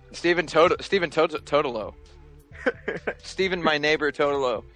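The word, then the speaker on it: Totilo: And Stephen Totolo, Editor-in-Chief of Kotaku. – Whoa! – You mispronounced his name. – No, I didn't. – Yeah, you did. – Oh, you did. – You totally did. – It's Totillo.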